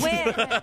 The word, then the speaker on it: Nguyen: No, she said win. The silent N.